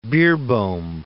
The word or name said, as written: Beerbohm